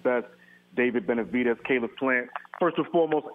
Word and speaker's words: Benavidez: Benavidez.